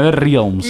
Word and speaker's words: NetherRealms: Había dicho Netherlands, perdón. – NetherRealms. – No. NetherRealms. – Netherlands. – Netherlands. – Borderlands. – Se pronuncia Realms. Realms.